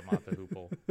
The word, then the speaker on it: Mott the Hoople: Oh, I don't know how they pronounce it, but it looks like it's spelled Mott or pronounced Mott the Hoople. – I think it is them, the Hoople. Yeah, Mott the Hoople.